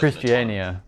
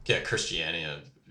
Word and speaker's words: Christiania: There's Christina in Denmark that is operated as, like... – Christiania. – Christiania. Yeah. Sorry, whatever. But, yeah. I suck at pronouncing stuff.